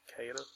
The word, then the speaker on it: Kana: It's K-A-N-A. Kana? – Kana.